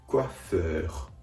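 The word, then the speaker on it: coiffeur: 続けて発音すると、コアフェー、コアフェーです。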